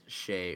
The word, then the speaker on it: Skjei: His last name is Shay, or at least that's how you pronounce it, and it's spelled, like, S-K... J-E-I.